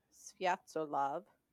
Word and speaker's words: Sviatoslav: After Igor's death, the rightful heir to the throne of Kievan Rus, 3-year-old Sviatoslav, that is how you pronounce that. – That's a great name. – Yeah. That's what I'm going to name my next cat. – Sviatoslav.